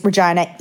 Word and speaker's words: Regina: And if you Americans think that it's Regina, it is Regina. Yes. – I'm going to call it Regina, though. I'm changing it. – It rhymes with vagina.